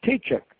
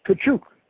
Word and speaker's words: Tkachuk: Mr. Francis, he says, you don't pronounce my name Tkachuk. The right pronunciation is Tkachuk.